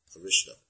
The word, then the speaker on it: Arishta: When Krishna was trying to capture the demon, because the Sanskrit's not pronounced here properly. Arishta.